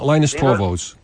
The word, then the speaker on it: Linus Torvalds: Well, that's Linus Torvalds. Linus Torvalds. – It's pronounced Linus.